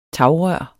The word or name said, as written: tagrør